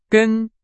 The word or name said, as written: 根